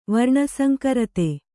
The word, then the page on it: ವರ್ಣಸಂಕರತೆ: kannada